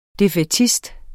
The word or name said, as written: defaitist